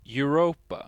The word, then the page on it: Europa: /ɔʏ̯ˈʁoːpa/